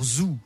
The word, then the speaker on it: Zoo: Peut-être qu'on devrait dire Zoo, parce qu'en anglais, les deux O doivent se prononcer OU.